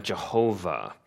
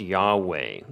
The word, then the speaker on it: Yahweh: And so in the old days, we called that Jehovah. Nowadays, it's more frequently pronounced Yahweh.